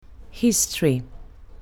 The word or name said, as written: history